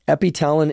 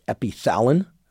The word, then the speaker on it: Epitalon: Epitalon is also sometimes spelled and pronounced Epithalon.